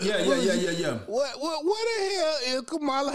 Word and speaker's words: Kamala: Yeah, Kamala.